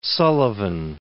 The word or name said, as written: Sullivan